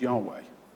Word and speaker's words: Yahweh: But it's written, and I'm sure you've seen it when you've read things, it's written YHWH, and we pronounce it as Yahweh.